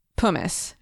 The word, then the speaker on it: pumice: And I want to go back to one term that you used, pumice. Is that how it's pronounced? – Right.